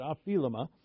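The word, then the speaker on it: aphilema (ὀφείλημα): So debts is aphilema. That's how you pronounce it, aphilema.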